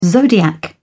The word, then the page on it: zodiac: British English